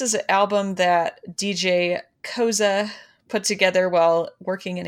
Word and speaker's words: Koze: I believe it's DJ Koze. It's okay. – K-O-Z-E. But since it's German, I feel like you'd put that E in there.